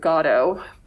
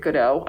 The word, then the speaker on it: Godot: And basically these two guys are waiting for, they pronounce it Godot, but I read it Godot.